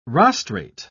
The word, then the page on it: rostrate: British English